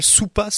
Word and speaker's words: Supa: Une web-série française qui est arrivée depuis quelques semaines sur YouTube, qui s'appelle Soupa Soupa. Donc ça s'écrit S-U-P-A.